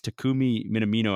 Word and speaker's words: Takumi Minamino: Takumi Minamino. I hope he's, I hope I said his name right. – Yes, um, perfect.